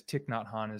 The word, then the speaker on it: Thich Nhat Hanh: And so if you haven't heard of him, it looks like Thich, T-H-I CK not Han, you know, but it's Thich Nhat Hanh is the way you pronounce it.